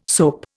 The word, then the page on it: soap: Indian